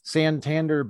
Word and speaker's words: Santander: He's an established industry leader trusted by major brands, Bloomingdale's, Hilton, Santander Bank.